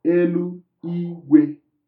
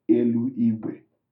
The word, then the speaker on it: Elu Igwe: So it is Elu Igwe and not Elu Igwe. – Okay. So the tone, the way I'm pronouncing Igwe can change the meaning.